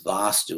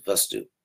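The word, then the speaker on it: vāstu: That's vastu with a long A. This is vastu, the original word.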